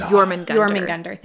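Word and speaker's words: Jörmungandr: Jörgunder? – It's the Jormungandr worm, I think is how you say it. – Nice. – I think it would make it a Jormungandr. – Jormungandr, thank you.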